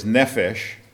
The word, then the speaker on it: nephesh: The Hebrew word is nephesh. You can spell it in English characters, N-E-P-H-E-S-H. I'm sorry, N-E-P-H-E-S-H. – M or N? – N-E-P-H-E-S-H. Nephesh, just like it looks.